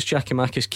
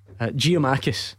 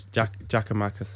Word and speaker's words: Giakoumakis: I mean, it was butchered many times on this show, and Atlanta have issued a pronunciation guide for anyone, because he was still called, what was the common one that you always hear? Giamakis.